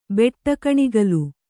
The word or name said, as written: ಬೆಟ್ಟಕಣಿಗಲು